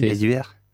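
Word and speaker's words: Eduard: Est-ce que vous connaissez Edouard Kill Non. Ah, je crois qu'on dit Kill. Edward. C'est Edward.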